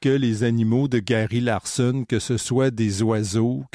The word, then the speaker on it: Larson: Un Américain, cette fois traduit en français, Gary Larson, exactement comme ça se prononce.